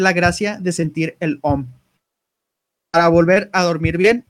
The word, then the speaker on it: om: El om que se pronuncia aum, aum.